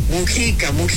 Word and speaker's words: Mújica: Mújica, luego digo mal el nombre, me regaña. Mújica con acento en la U.